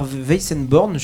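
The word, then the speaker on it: Weissenborn: Alors justement, puisque tu parles de luthier, difficile de ne pas parler de ton instrument, la guitare Weizenborn, je ne sais jamais tellement comment le prononcer, mais voilà. – Tu l'as bien dit.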